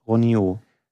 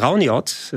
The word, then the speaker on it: Rauniot: So, ich hätte das auch im ersten Moment gedacht, aber Rauniot ist wohl die korrektere Aussprache. Es ist kein französisches Spiel, sondern aus Finnland.